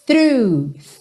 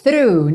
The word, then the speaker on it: through: O GH do final é mudo e through é fechadinho. Lembra só de começar fa-fa-fa com a linguinha lá na ponta dos dentes da frente. Through, ok? Então, and I'm soaking through and through.